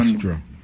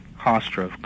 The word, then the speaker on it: Hofstra: Was it Hofstra University? I'm pronouncing it wrong. Was on Hofstra. – Hofstra, correct.